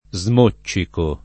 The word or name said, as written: smoccicare